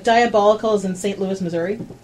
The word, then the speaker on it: Dyeabolical: And I should mention it's spelled D-Y-E-A-B-O-L-I-C-A-L. Diabolical.